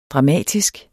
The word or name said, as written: dramatisk